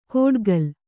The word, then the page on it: ಕೋಡ್ಗಲ್: kōḍgal